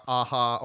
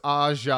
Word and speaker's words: Aja: I literally Googled the pronunciation, so it's, of course, I'm not saying it with the French accent, but it is Alexandra Aja, or Aja, not Aha, or Aha. – Aha! No, it's Aja.